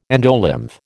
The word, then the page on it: endolymph: American English